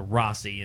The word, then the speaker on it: Rhossi: Well, it's spelled R-H-O-S-S-I, so I could have, you know, the vowel and two consonants in between another vowel. I should have probably shortened that out to Rossi and Rossi. – Made it a long O, yeah.